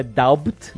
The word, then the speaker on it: doubt: Aliás, entendeu que a palavra é doubt, mas ela falou doubt. – Pois é. Sem B. silent B. Silent B. Obrigado. – doubt. – Não é doubt.